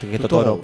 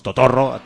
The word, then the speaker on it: Totorro: Entonces se pronuncia igual que Totoro, porque como no pronuncian las dobles R's, pero nosotros decimos Totorro, castizamente.